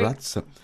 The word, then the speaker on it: Bratz: Prononce bien Bratz? – Oui, oui. – Bratz.